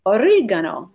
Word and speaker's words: origano: My nonna is the one that taught me this, and the the um, word in Italian for oregano is origano, mm. origano.